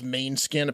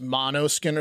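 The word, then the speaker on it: Måneskin: I just did a retort to someone that was talking shit because I couldn't pronounce main skin. Apparently, it's mono skin or something because there's a little circle on top of that.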